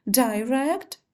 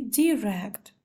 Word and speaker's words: direct: Многих смущает произношение английского слова direct, потому что в словарях можно найти два варианта произношения direct и direct. Первый вариант direct, он более современный. И этот вариант произношения указывают в словарях современных издательств. Вариант direct – это более устаревший вариант произношения, но он тоже является правильным.